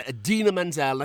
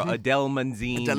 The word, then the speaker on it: Idina Menzel: So remember back at the Oscars where John Travolta totally just butchered her name, Idina Menzel or Adele Menzine or something like that.